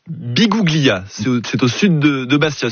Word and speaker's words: Biguglia: Bonjour Christopher, bonjour Valentin, et oui c'est bien à Biguglia. – Voilà, on le prononce bien comme ça, c'est avec parfait.